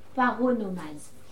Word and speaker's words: paronomase: C'est pas paranomase. – Presque ça, tu l'as. Paronomase. – Paronomase.